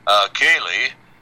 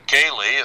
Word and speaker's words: Koehle: I'm going to attempt to pronounce his name correctly. The initial E-N. And this is a German name, K-O-E-H-L-E, Kehle. If you had an R, you'd have a very famous Missouri Synod Lutheran theologian whose name was Kehler. But E. Orlean Cayley, if I'm pronouncing his German name, and he's probably Americanized it, was also interviewed by John Stadtmiller yesterday.